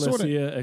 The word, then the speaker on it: ecclesia: But there is this word, and you can help me if I'm pronouncing it right or wrong here. I don't think I ever pronounce it correctly, but ecclesia or... – It's ecclesia.